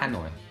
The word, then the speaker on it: Hanoi: Hanoi.